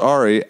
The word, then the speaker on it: Ari: It's pronounced Ari.